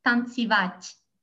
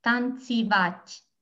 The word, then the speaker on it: танцевать: So it's танцевать, танцевать. – One more time, please. – Like dance, just like in German, tanzen, tanz, танцевать.